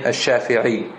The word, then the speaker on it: Ash-Shafi'i: And hence, the name Ash-Shafi'i. Shafi'a, named after his third grandfather, Shafi'a. Shafi'i. That's how you say it in Arabic. You add a ya to the end to say that you belong to that.